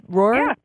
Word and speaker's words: Rohrer: Rohrer? – Yeah. – Okay, great. – Yeah, it's ancient Swiss for plumber, but it's pronounced like the noise a lion makes. – Roar.